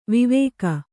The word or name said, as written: ವಿವಾಕ